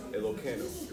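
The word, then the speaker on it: Elokeinu (אלוקינו): Elokeinu. We don't do the Yud. Some people say Elokeinu. – Oh, Keinu? Yeah, no way. – Some people. – Oh, yeah. Steak. – The Yud is silent.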